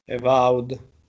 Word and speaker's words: Avowed: vorrei Evowed, Avowed, Evod, come si dice, correggimi tu. Evaud. Evaud